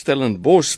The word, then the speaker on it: Stellenbosch: die uitspraak is Stellenbosch, maar ons spel dit met 'n B O S C H. En Rondebosch is dieselfde, dit word met 'n B O S C H aan die einde gespel, maar ons sê Stellenbosch en nie Stellenbosch nie.